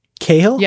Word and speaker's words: Cathal: Okay, the T is silent. – Cahill? – Yes.